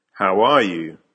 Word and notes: how are you: American English

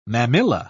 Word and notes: mammilla: British English

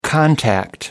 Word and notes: contact: American English

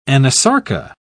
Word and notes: anasarca: British English

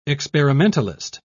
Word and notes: experimentalist: British English

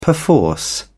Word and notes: perforce: British English

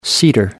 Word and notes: cedar: American English

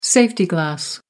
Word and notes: safety glass: American English

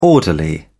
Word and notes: orderly: British English

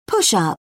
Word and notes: push-up: British English

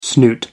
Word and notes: snoot: American English

